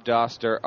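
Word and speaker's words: Dauster: It's at Rob Doster, R-O-B-D-A-U-S-T-E-R. – So you actually pronounced my name right. I'm proud of you. – Doster. Why? Yep. What do some people say? Douster? – Douster, yeah. – Douster. No, Doster.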